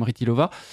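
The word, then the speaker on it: Chytilová: Vera Rytilova, ça s'écrit Chytilova, mais on prononce à la tchèque à peu près quelque chose comme Rytilova.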